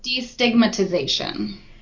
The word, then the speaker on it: destigmatization: You speak a lot about mental illness and demagnetization. Did I say it right? No. – Oh, destigmatization.